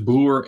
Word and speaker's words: Broer: I think it's only pronounced as Broer in the Netherlands, and I think if English people would see it and read it, they would say Broer or something. And in Germany, it's Broer. So the pronunciation is different everywhere, and I thought Fratello, it's the same sound, same pronunciation everywhere.